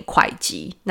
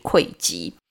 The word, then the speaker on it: 会稽: 他在老家会稽附近的东山住了下来。会稽这两个字啊，我以前都会念会稽，那这次特别去查了一下，它的正确读音应该是会稽。